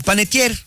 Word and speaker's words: Panettiere: ¿Panettiere? – Panettiere.